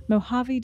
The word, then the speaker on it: Mojave: The serene scene is a callback to Pike's younger years in the saddle while growing up in California's Mojave Desert.